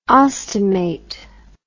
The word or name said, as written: ostomate